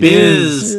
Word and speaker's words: Bhiz: There's Biz. B-H-I-Z. – Biz. Biz. Yep. Biz. Totally no a name I made up on the spot. Except it's spelled with an H. Biz.